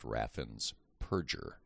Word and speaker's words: Raffensperger: He won by 50,000 votes, and then repeated his efforts again in 2022 through his Secretary of State, Brad Raffensperger, whose name, ironically, is correctly pronounced Raffensperger.